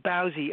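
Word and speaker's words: Bowsie: Well, the Bowsy thing, we pronounce it Bowsy. It's kind of a Dublin expression for a kind of ne'er-do-well character, a kind of drunkard, slothful, you know.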